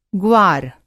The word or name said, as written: ग्वार